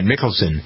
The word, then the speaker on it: Mickelson: So I always pronounce it Mickelson, but you're right. Mickelson is the way he pronounces it.